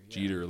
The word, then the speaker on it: Jeter: Jeter, like Peter with a J.